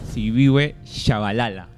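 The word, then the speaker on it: Siphiwe Tshabalala: Sifigüe Chesbalala. Epa, Javier Hernández, Catlego Mapela o Andrés Guardado. – A Sifigüe Chabalala.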